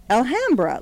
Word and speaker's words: Alhambra: I think you say it Alhambra. – Alhambra, like ham, like ham bone.